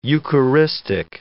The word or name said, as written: eucharistic